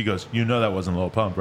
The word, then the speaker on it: Lil Pump: And she's like, oh, did I say it like that? I'm like, yeah, you said Little Pump. And that was It's not Little Pump.